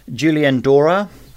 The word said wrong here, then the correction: Julien Doré: Do you want me to say? – Yeah, I do. – Fever. Julien Doré and Fièvre.